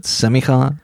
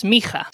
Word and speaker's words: smikha: Is that how you pronounce it? Semicha? – It's Smicha. Sometimes people spell it in English, S-E-M-I-K-H-A or whatever, but that E is actually just representing a phoneme that is not usually represented in English with an E.